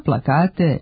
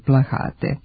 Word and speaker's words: plakat: Ni plakat nije naša reč. Uzeli smo je iz nemačkog, a ponekad u govoru može da se čuje kao plahat sa H, što je nepravilno.